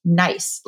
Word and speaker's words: gneiss: There is a type of soil that we find frequently throughout Austria in the Wachau region, among others, and it's pronounced nice, like make it nice. It's spelled G-N-E-I-S-S. So if you did not know how it was pronounced, it looks like Gneiss, right?